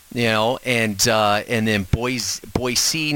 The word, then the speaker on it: Boise: I'm trying to give people a flavor of Boise. I'm saying it right, right? Boise, not Boise. – See, yes, right? That's right.